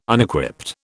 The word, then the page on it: Unequipped: British English